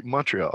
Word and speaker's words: Montreal: So I'd like to welcome my special guest and good friend from Montreal, or Montreal. You guys don't pronounce the T, right? – Yeah, you know how, Montreal.